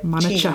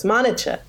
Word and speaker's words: Monicha: Either that or they pronounce it with the CH becomes like Monica. Cheese. So it's Monica. – Monica. – But I was like, no, it's CH like cholesterol.